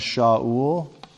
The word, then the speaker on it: Shaul: Shaul sounds like a weird Middle Eastern name, doesn't it? But it's the name Saul. And this kind of gets into the pronunciation thing and how it's a little bit problematic, because everybody knows the king that preceded David, right? We call him Saul. We don't call him Shaul usually, but the name is exactly the same in Hebrew.